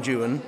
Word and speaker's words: Juan: So in the opening few stanzas of Don Juan, he rhymes Juan with new one. Several times, so that's how we know it's pronounced like that in that sense.